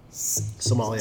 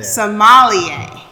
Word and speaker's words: sommelier: Sommelier. – Sommelier.